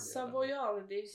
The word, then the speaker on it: Savoiardi: Savinardi eller Savinardi. – Savoiardi, kex.